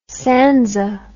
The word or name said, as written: sanza